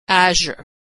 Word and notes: azure: /zh/

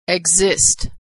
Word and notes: exist: g

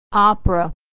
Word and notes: opera: American English

